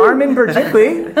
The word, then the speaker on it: Armen Berjikly: Armin Berdikli.